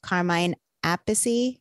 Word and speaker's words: Carmine Appice: Tommy Davidson, Zach Wild, Carmine Appice.